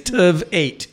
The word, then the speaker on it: Tveit: Which is pretty easy. It's Tv8, right? It's like one more than Tv7.